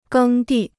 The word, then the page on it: 耕地: gēng dì